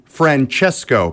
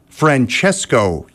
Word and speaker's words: Francesco: Francisco, that's fun to say. – Francisco. – It's actually Francesco, pal. It's pronounced Francesco Yates with Better to Be Loved on 96.1 The Rush.